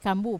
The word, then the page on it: kambu: wamesa